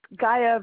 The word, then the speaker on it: Gaia: It's Gaia Venus, and you probably need me to spell that. It's G-A-I-A.